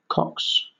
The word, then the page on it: cocks: British English